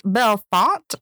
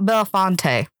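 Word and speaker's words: Bellefonte: It's pronounced Belfont, not Belfonte. And we said it all up and through that episode. So whoops. – Dang. That's the English hard language. Because the way it's written, you would think it's Belfonte, but okay.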